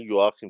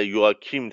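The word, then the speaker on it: Joachim: ما خ نداریم و حالا یواکیم تلفظ میکنیم و اون جی رو هم که یه تلفظ میکنیم، مثلا در واقع مثلا نمیگیم مثلا جاکوبسن، مثلا میگیم یاکوبسن، به طور مثال میگم.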